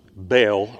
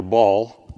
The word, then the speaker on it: Baal: She worshiped Baal, or Baal, B-A-A-L, however you want to pronounce it, right?